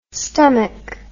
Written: stomack

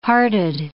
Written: hearted

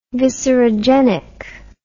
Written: viscerogenic

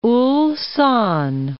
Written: Ulsan

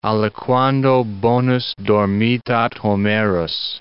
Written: aliquando bonus dormitat Homerus

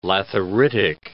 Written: lathyritic

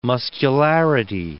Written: muscularity

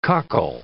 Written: coccal

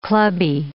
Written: clubby

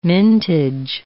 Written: mintage

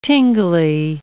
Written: tingly

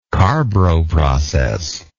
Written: carbro process

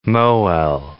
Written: mohel

